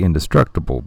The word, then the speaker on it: Indestructible: It looks like indestructible, but obviously it's pronounced Indestructible.